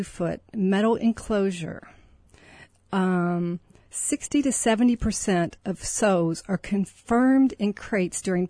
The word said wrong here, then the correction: sows: Sows, I'm sorry. – That's okay. That's okay. – Sows, it rhymes with cows.